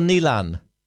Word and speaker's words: Neilen: Is your name pronounced Nevan or Neilan? – Neilan. N-E-I-L-E-N. – N-E-A-L-E-N.